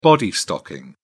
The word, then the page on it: body stocking: British English